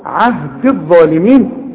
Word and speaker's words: عهدي: الغي الياء دي بتاعت عهدي من النطق اذا وصلت، انما تثبت اذا وقفت، قال لا ينال عهدي عند الوصل عهد الظالمين، لانها ساكنه واللام ساكنه.